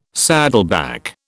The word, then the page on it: saddleback: British English